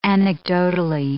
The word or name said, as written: anecdotally